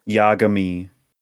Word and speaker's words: Yagami: Yagami.